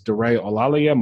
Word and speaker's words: Daray Olaleye: My Instagram handle is Daray Olaleye, my first and last name.